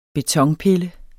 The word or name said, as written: betonpille